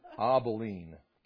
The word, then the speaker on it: Abilene: And I think I said Abilene. Like, isn't there a city in Texas called Abilene, Texas? Well, they don't pronounce it right. It's Abilene.